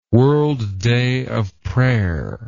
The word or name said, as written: World Day of Prayer